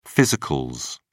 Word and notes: physicals: British English

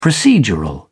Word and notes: procedural: British English

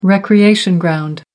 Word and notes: recreation ground: American English

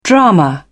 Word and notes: drama: British English

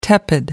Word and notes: tepid: American English

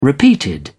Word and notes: repeated: British English

